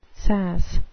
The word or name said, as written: sass